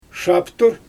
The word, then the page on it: ʃaptur: Xyzyl